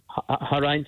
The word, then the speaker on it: Horion: Horrain Studios. – Spell that for us. – H-O-R-I-O-N. – Horrain Studios. Okay. – Yes, correct, yeah.